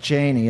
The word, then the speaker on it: Cheney: Anyway, I haven't seen the movie, but he really looks like Cheney in this movie.